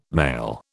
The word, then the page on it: male: British English